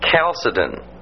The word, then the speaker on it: Chalcedon: And then 451, the Council of Chalcedon. Remember, that's pronounced with a hard CH, like Christ. You know, a K sound, Chalcedon, not Chalcedon.